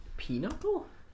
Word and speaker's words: Pinochle: Pinochle? – Okay. – I thought it was something like... – It's Pinochle, so P-I-N-O-C-H-L-E. – But it's pronounced Pinochle.